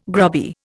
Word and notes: grubby: Indian

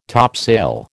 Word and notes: topsail: American English

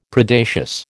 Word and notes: Predacious: American English

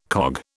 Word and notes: cog: British English

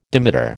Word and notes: dimeter: American English

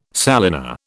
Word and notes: salina: British English

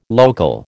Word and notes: local: American English

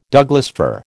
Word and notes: Douglas fir: American English

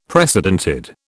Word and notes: precedented: British English

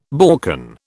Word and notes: Balkan: British English

